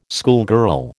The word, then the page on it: schoolgirl: American English